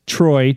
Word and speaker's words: Troy: Somebody wrote me a message and spelled it E-R-I-N. That's wrong. I'm just letting you know. That's wrong. – And my name is Troy, T-R-O-Y, not Tony.